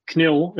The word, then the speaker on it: KNIL: Also have all the SS minis primed and half painted, but now I'm working on the knil, is how you pronounce it in Dutch, to get them ready for pictures, and a small game on the 16th of December in the Museum of Overloon.